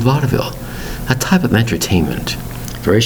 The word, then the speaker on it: vaudeville: Two syllables into three. Vaudeville. Spelled V A U D E V I L L E. Vaudeville. And you say to yourself, it looks like two syllables when you spell out the word. V A U D E V I L L E but there's three syllables in there.